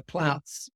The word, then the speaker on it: Plautz: I've been practicing my Plautz several times, so glad I got it right. – It was perfect. It was perfect. Exactly how it's pronounced. It's a tricky one.